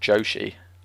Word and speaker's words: Joshi: The only other exposure I've had to other promotions is I've had a couple guys link me to, I think it's called Joshi. I think I'm pronouncing that right, which is yeah, that's correct.